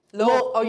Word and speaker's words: Louisville: Louisville. You said it right. Hey, my boss at the CEP would be proud of you. He's from Louisville. – Louisville. – You don't mess around. No. It's not Louisville.